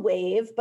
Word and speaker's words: WEIV: So it's called Wave, but we spell it a little strangely. It's spelled W-E-I-V, and that's because it's view backwards.